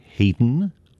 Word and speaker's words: Haydn: The Waltz, Joyousness, from the Moods Suite by an English composer of lighter music named Haydn Wood. And yes, Haydn is spelled the same as Haydn, but of course pronounced differently.